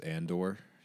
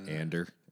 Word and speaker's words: Andor: Well, is this our guy who mispronounces Andor? Ander.